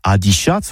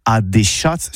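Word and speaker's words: adishatz: Eh oui, adicias, que c'est prononcé au tabé, adichats, voire adéchats, surtout en Gascogne toulousaine.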